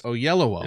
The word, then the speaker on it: Oyelowo: Oyelowo. Yeah. That is how you say his name.